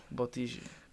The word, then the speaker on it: Bottiggi: If I don't use the accent, it comes Bottiggi. Out wrong. Bottiggi.